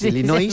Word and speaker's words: Illinois: Es un estado de Estados Unidos que se pronuncia así. Es Illinois, según tú.